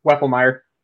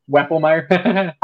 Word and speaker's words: Wefelmeyer: Wefelmeyer. – Thank you, sir. One um, more time. Wefelmeyer.